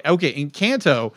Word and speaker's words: Encanto: So in the news, the only thing I could really find, I know Encanto. Am I saying that right? – Dilett says, yes, you are. – Okay. Encanto.